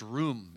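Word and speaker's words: rum (רום): The Hebrew for extol is pronounced rum. It's spelled R-U-M.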